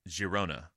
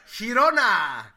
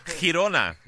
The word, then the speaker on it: Girona: This will be against Girona. I always say it wrong. – Girona! – Girona. All right.